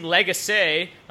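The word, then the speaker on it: Lagacé: They're down to Maxime Legace, which I found out that's how you pronounce it on the broadcast the other night.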